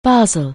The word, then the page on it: baseball: anglais